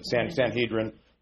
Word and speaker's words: Sanhedrin: He was the youngest serving member of the Jewish Sanhedrin, which is the way you're supposed to pronounce it. Sanhedrin, that's the way we kind of say it in English. But if you say it in Hebrew, Hadrin.